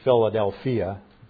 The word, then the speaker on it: Philadelphia: Philadelphia, actually Philadelphia would be the Greek pronunciation.